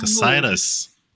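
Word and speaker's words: Cocytus: So when I talked to Bill Tiller, it was the first time I heard the name pronounced out loud. So they are called Cassitans. And the planet is called Cassitus.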